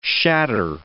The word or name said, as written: shatter